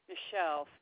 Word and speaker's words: Michelle: Michelle, spelled like Michael with an E at the end.